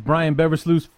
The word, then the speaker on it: Brian Beversluis: And here to help us preview this week's battle between those two teams, Brian Beversluis from Cat Scratch.